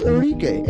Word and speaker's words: Arike: Oh, no, it's Arike. A-R-I-K-E. – Yep. – Arike. – Yep, that's exactly how you pronounce that.